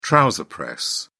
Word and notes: trouser press: British English

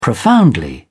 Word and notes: profoundly: British English

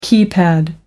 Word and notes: keypad: American English

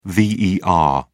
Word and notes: ver: British English

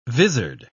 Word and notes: visard: British English

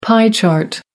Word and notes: pie chart: American English

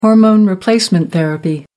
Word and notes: hormone-replacement therapy: American English

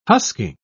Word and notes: husking: British English

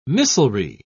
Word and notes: missilery: British English

